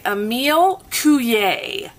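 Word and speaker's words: Émile Coué: It's by Emile Coué.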